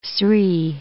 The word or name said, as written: Sri